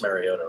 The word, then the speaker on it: Mariota: It looks like Mariota and Marcus Mariota, right? That's how you say his name.